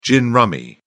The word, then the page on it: gin rummy: British English